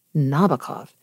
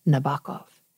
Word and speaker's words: Nabokov: It's not Nabokov, it's Nabokov.